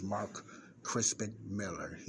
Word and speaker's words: Mark Crispin Miller: Okay, without any further ado, okay, this journalist, Mark Crispin Miller, I think I pronounced his name wrong the last time, but his name is Mark Crispin Miller.